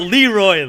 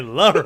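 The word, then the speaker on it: Le Roi: You gotta say his name right. Leroy. – Leroy. – Leroy. – Not Leroy, Leroy. – Leroy spelled L-E space R-O-I.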